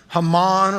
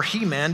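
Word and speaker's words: Heman: Haman, or He-Man, depending on how you want to pronounce it, wrote one.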